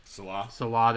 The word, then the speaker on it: Salah: Salehi? – Salah, there you go.